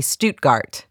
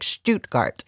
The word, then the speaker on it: Stuttgart: the German city Stuttgart, and she corrected me appropriately and said it was something like Stuttgart, but we ended up sticking with Stuttgart because I couldn't bring myself to say it right.